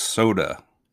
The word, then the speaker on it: Sodor: I believe it's pronounced soda.